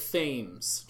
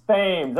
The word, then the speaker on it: Thames: I know that you begrudgingly have to say every name wrong, but it is Eric Thames, just so you know. – Thames or no? I say it like the river. – no, no, it's Thames. Thames. Okay, Thames. You can do gotcha.